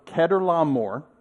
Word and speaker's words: Chedorlaomer: After his, it's Abram's return from the defeat of Chedorlaomer. Yes, that's how you say it.